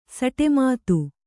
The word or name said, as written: ಸಟೆಮಾತು